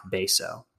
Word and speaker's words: Betio: It's spelled B-E-T-I-O and pronounced Baso.